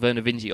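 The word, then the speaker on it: Vernor Vinge: Vinge, and I was unsure how to pronounce the name Vinge, so I settled on Vinge. But the other day, I listened to a podcast interview with Verna Vinge, and he calls himself Verna Vinge.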